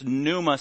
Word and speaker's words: pneuma: It's got a P in Greek. Pneuma, pneuma. And then in English, it's pneuma, silent P,